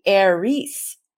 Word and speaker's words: Aries: If you're interested in understanding the Eris, which I have now learned, it's supposed to be Aries. If we were gonna pronounce it correctly, Aries.